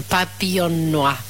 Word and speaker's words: Papillon Noir: Yo creo que son esas, son tantas, ¿no?, razones que me empujaron a escribir, a plasmar mis ideas a través de Papillon Noir, Mariposa Negra. – Esa es la pronunciación, Papillon Noir. No lo iba a poder pronunciar no, bien. Está bien, lo pronunciaste muy bien.